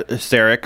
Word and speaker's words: Szarekh: How'd you say Sarek? – Yeah, Sarek. – Silent Z? – Usually you just call him the Silent King. – No, I meant the Z. – The Z? – Because it's spelled S-Z-A-R-E-K-H. Silent Z, Silent H.